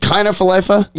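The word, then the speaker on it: Kiner-Falefa: Is it Kiner Falefa? – Yeah.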